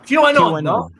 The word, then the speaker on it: QAnon: QAnon.